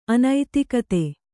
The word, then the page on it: ಅನುದ್ಗತ: Kannada